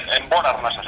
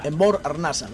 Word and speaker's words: Enbor Arnasa: No sé si lo he dicho bien, Enbor Arnasa, si estoy diciendo bien la acentuación. – Sí, Enbor Arnasa sería. – Enbor Arnasa, ¿ves?